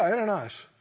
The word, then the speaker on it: Ananias: Yeah, Ananias.